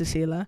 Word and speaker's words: Saylah: So to spell it as S-A-Y-L-A-H. Because it rhymes with Pela, which is quite cute also. We like to say Pela to Sela.